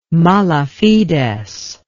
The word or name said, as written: mala fides